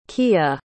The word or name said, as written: Kiya